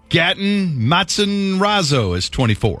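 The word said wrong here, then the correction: Gaten Matarazzo: How do you say it? – Oh, I have no idea. Matarazzo? – Gatton? – I think you said it right. – Gatton. – Yeah. – Matarazzo. – Gatton Matarazzo.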